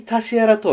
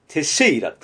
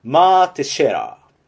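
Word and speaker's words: Mark Teixeira: でも実際に、アメリカ国内の中継を見ていても、現地で場内アナウンスとか観客の皆さんが言ってるのを聞いても、絶対タシェアラとは言ってないんですよ。テシェイラって言ってるんですよね。マークテシェイラなんですけど、マークのクーは発音せずに、マーテシェラ。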